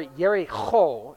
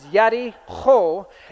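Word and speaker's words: Yericho (יְרִיחוֹ): Or Yericho is how it would be pronounced, but that's a lot of spitting. But it is Yericho.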